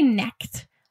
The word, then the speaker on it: Knecht: It's called Relief Map by Rosalie Knecht. I think that's how you say her name. It's K-N-E-C-H-T. – That sounds right.